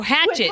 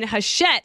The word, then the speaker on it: Hachette: No, Hatchet, you said, with Hatchet. And it is spelled like that, but it's like French. And I said, oh, you mean Hachette.